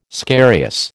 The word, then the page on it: scarious: American English